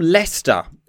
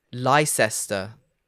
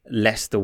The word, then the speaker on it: Leicester: Um, so you're from Leicester,